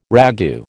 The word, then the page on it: ragout: American English